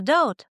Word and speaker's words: doubt: Слово «doubt» — сомнение. С немой «be».